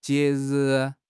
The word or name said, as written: jie2 ri4